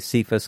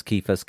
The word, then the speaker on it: Cephas: People pronounce it different ways, Cephas, Kephas, Kephas, whatever. I've heard it all the ways.